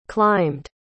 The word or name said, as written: climbed